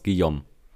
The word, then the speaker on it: Guillaume: Man, man spricht es genau so aus, Guillaume.